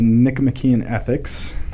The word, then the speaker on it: Nicomachean Ethics: So we're going to be reading the Nicomachean Ethics.